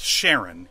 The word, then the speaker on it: Charon: And it is pronounced Sharon, even though it is spelt with a CH.